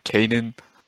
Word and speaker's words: Canaan: B, Canaan.